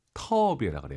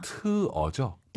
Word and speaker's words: 特: 선생님, 정말 많은 분들께서 터비에라 그래요. 터비에. – 아하. – 트어죠? – 对, 特.